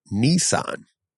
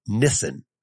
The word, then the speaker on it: Nissan: The Japanese language doesn't have a N-I-H sound like that. The all I sounds in Japanese are like ni. The double S does make it carry to the first syllable. So it is Nissan, not Nissan.